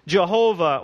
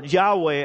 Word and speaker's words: Yahweh: Psalm 2 is a messianic prophecy that details the rebellion of the nations against Jehovah, or Yahweh, as the term is more properly pronounced probably in Hebrew, and his Messiah.